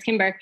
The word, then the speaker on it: Kimber: But as we just went over, my name is Kimber. It's a strange name because it's spelled with a C. So my mom was really obsessed with C's, but it's not Simber, it's Kimber.